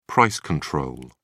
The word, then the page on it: price control: British English